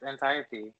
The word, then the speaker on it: Antiope: Anatope. – Antiope. Yeah. Oh. – no, they say it in the movie. It's Antiope.